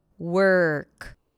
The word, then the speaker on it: work: Basically, what happens is that you transition from the W sound to the R, skipping the O, skipping the representation of the O. Work. Work.